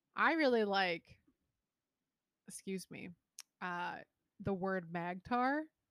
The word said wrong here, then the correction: magnetar: So that's Magnetar. – Wow, and I butcher it.